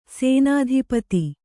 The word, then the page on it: ಸೇನಾಧಿಪತಿ: Kannada